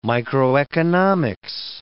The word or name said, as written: microeconomics